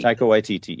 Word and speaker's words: Waititi: Taika Waititi, yeah.